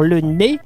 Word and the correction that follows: le Lez: Non, c'est le lez déjà, tonton, c'est pas un N, c'est un L.